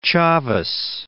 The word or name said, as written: Chavez